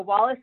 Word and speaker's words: Wallis: That's another way of reaching me, Shannon Wallace Solutions. – And spell your last name. Because it's not your typical Wallace. – Exactly. Thank you so much. So Wallace is spelled W-A-L-L-I-S. So like Wall is flawless.